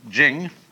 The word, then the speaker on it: Ching: And the book is called the Tao Te Ching, because again, there's no apostrophe after the T, so it's pronounced de, and Jing. There is no apostrophe after the CH, so if there were, it would be pronounced Ching, but it's Jing, like a J.